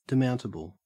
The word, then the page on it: demountable: Australian English